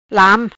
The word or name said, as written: Lam